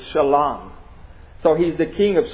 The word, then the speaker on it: Shalom: Now in the Hebrew, Salem would be pronounced, the S would be pronounced with the SH sound. Shalom. Shalom. How many of you know what Shalom is?